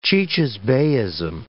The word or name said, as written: cicisbeism